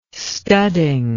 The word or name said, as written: studding